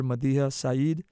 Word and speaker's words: Madiha Saeed: I'm here with a very special guest, Dr. Madiha Saeed.